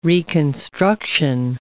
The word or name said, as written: reconstruction